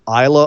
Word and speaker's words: Islay: It's spelled I S L A Y. Um, so it's it looks like Islay, but it sounds like Isla.